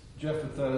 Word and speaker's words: Jephthah: It's kind of, if you look at it in Hebrew, I mean, in English, I first would say, just by the English spelling of it, Jephthah. Jephthah is how I pronounce it, but if you look at the Hebrew, it has a very much different sound to it, so we'll talk about that in the overview of this this lesson six.